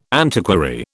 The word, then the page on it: antiquary: British English